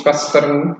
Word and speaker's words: Schwestern: It's called in German it's the Vampire Schwester or so. It's Schwestern.